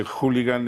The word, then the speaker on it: hooligan: Bueno, es trisílaba, solo que hooligan es esdrújula y jabalí es aguda.